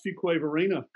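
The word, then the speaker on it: Fuquay-Varina: And Daryl, I'm happy to hear you pronounce Fuquay Varina correctly. I know you've been here a few times, but a lot of folks have trouble saying that. It sounds like an infectious disease, but we're actually just south of Raleigh, North Carolina.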